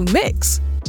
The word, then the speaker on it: Mx: from Miss or Mrs. to Mix. When it's written, it's spelled MX, period.